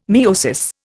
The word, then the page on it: miosis: Indian